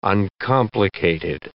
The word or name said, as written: uncomplicated